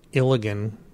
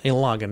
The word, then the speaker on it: Ilagan: I pronounced it Ilagan, because I thought it was like Gilligan. But without, yeah. And so I pronounced it Ilagan, which clearly it's not. It's the Ilagan Mission.